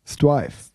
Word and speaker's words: Streiv: S-T-R-E-I-V. – Bitte den Namen nochmal aussprechen. – Strive.